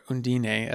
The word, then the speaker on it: Undine: And finally, a film called Undine, or Undine, as they kind of pronounce it in the film, which is a German film by director Christian Petzold.